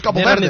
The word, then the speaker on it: Cabo Verde: Yo, that's how you pronounce Cabo Verde.